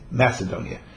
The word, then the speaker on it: Macedonia: The Gemara says with a kuf, but we in English we pronounce it as if it's a soft C, Macedonia.